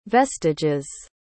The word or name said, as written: vestiges